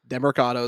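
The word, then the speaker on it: Demercado: Next on the list, Imari Demarcado. How do you say that? – Demarcado is correct.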